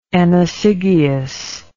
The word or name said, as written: Ennosigaeus